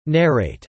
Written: narrate

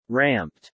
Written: ramped

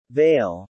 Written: veil

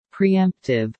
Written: preemptive

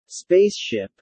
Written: space ship

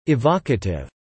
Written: evocative

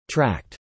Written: tracked

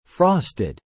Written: frosted

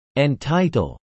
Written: entitle

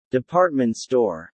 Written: department store

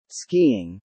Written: skiing

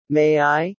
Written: May I ...

